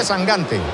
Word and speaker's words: Sangante: No, Sangante. Se pronuncia Sangante. – Yo pronuncio como me digáis. – Bueno, eso he leído yo, que dice que es Sangante. – De hecho, me ha dicho, tú acuérdate de Mangante.